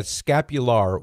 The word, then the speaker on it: scapular: I was just telling your screener, I'm not sure if it's scapula or scapular, so if I mispronounce it, please correct me. – Right? Yeah, scapular with an R at the end.